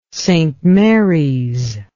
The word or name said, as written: St Marys